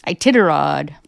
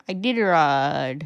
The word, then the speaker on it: Iditarod: Ita, how do you think it's pronounced? – Iditarod. – Iditarod? Iditarod? – Iditarod.